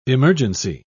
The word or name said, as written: emergency